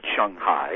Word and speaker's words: Shanghai: A little more Shang rather than Shang, but Shanghai is fine.